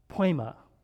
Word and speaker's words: poema: In fact, in the original language, it's pronounced poema. What word do you think that is in English, or sounds like in English? Poem, right? Poem.